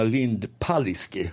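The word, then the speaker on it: Lind-Palicki: Lena Lindpaliski uttalas det, har jag förstått, förordar dem en språkreform.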